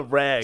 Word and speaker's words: reg: Is it reg or reg? – On the reg. – On the reg. – It's a hard G.